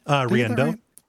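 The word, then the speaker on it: Riendeau: This game has been called by some, I know, Danielle Riandendo. I mispronounced her last name, right? – Riando. Riando. – Yeah, Riando.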